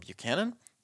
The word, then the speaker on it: Buchanan: My name is Adam Buchanan.